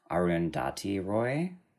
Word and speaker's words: Arundhati Roy: got sent that, that got sent around by I believe his name is pronounced Arundhati Roy.